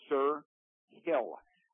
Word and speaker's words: Sir Hill: And let me spell that for you so you can understand my pronunciation. S-I-R, that is his first name, and his surname is H-I-L-L, Sir Hill, a 6'2" redshirt junior out of Crosby, Texas.